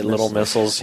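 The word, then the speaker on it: Litomyšl: Little missals, yeah. – Yep. Explain that. – Yeah, Little Missal is spelled Litomysl, and it's a little town in the Czech Republic,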